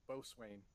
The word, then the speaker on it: Boatswain: Is that how you say it, Boatswain? – Yep. – Is it sure it's not like pronounced Boatswain, mate? – Nope, Boatswain.